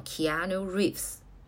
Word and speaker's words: Keanu Reeves: 这个主演就叫做 Keanu Reeves。